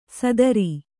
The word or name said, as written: ಸಾತಾರಿ